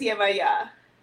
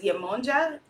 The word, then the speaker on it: Yemaya: There's Yemonja, Yemaya, depending on where you are. Again, the pronunciation changes. Yemaya, Yemanja, Yemaya, depending again on the location and where you are.